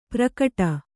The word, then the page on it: ಪ್ರಕಟ: prakaṭa